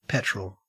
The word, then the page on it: petrol: Australian English